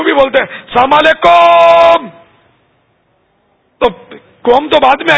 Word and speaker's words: السلام علیکم: صحیح تلفظ ہے السلام علیکم میم پر پیش ہے میم کے بعد واو نہیں ہے